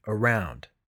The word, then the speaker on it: around: I'll start with the preposition, around. It has two syllables, with the stress on the second syllable.